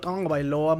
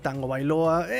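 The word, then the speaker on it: Tagovailoa: Pronuncien todos conmigo, Tongo Bailoa. Muchos le dicen Tango Bailoa.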